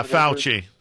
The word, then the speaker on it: Fauci: Fauci? – Yeah, Fauci, yeah.